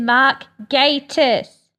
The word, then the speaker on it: Mark Gatiss: First things first, for the 500th millionth time, it is Mark Gatiss.